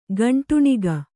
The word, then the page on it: ಕುಂಟಣಿಗ: kuṇṭaṇiga